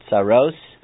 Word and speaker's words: tzaros: Somebody mentioned the word tzoras, and they say, oh, isn't tzoras tzoras? And tzoras is like a catch-all phrase. It's really a Yiddish pronunciation of the word tzaros, from the word tsar, tsadi resh.